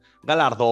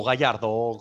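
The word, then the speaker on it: Galhardo: Pues eso, pues yo digo a nuestro amigo Tiago Galardo o Gallardo, como cada uno lo quiera pronunciar. – Galardo.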